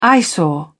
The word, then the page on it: eyesore: British English